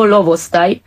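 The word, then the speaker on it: lovostaj: To jest i w ogóle dyskutowaliśmy z Krzysztofem o tym, jak w ogóle wymówić słowo lowostaj. Aha. Lowostaj, bo ona ma też taki akcent, który tam uderza.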